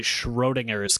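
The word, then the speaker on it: Schrödinger's: Like, you can't even see the throne in your cat. – I was trying to say Schrodinger's cat, but mangling the pronunciation.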